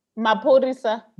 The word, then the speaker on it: Maphorisa: Ma- wait, why, why you say his name is DJ Mapolisa? Maphorisa. Maphorisa. Maphorisa.